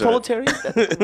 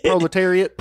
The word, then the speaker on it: proletariat: Say, what is a proletariat? What? – It's proletariat? – Yeah, it's a blue-collar worker. – Why did you say that? – Like, rhymes with chariot, proletariat. – Proletariat.